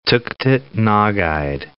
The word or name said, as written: Tuktut Nogait National Park